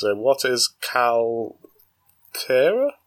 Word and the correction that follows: Kreia: Kreia, Cal Kreia.